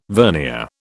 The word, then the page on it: vernier: British English